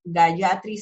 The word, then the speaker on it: Gayatri: Gayatri se escribe G-A-Y-A-T-R-I, Gayatri.